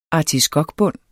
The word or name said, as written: artiskokbund